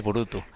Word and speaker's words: Burutü: ¿O cómo lo pronuncia Efectivamente, lo has dicho muy bien, es Burutu. Eh, tiene eh, la dificultad de pronunciación que tiene una diéresis al final, dos puntos sobre el au.